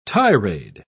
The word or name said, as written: tirade